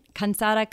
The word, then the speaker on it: cansada: So it's the only difference is an N, cansada, casada.